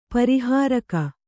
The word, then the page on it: ಪಾರಿಹಾರಿಕ: pārihārika